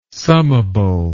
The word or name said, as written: summable